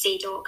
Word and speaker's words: Zadok: Azor, Zadok is spelled like Sadok, but it's Zadok. I would think like Zadok the priest.